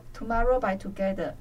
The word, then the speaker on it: Tomorrow X Together: 团名写作 Tomorrow X Together，念作 Tomorrow by Together，嗯、呃，但韩文好像都把他们称作 Two-but-Two。